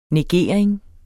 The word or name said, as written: negering